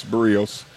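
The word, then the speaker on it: Berríos: We'll have to do some investigating on this, though, because when Barrios first came up, we thought, at least I thought it was Barrios at first for a little while, and then I was corrected and said, no, it's Barrios.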